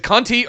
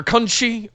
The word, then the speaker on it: Conti: Is it Conti or Conchi or? – Conti. Yeah, it's like a Brazilian pronunciation for Conti. Right. – The Portuguese pronunciation.